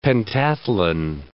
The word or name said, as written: pentathlon